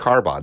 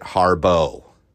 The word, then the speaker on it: Harbaugh: Now, everybody listening, that sounds like Harbaugh, doesn't it? No, this motherfucker pronounces it Harbo.